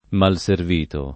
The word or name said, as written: malservito